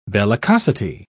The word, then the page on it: bellicosity: British English